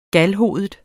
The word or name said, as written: galhovedet